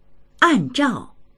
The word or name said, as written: an4zhao4